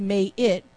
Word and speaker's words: Maat: Because if Mayet, M-A-A-T, but it's pronounced May-it,